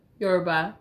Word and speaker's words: Yoruba: Yoruba Yoruba.